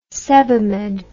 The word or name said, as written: sebamed